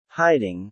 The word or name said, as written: hiding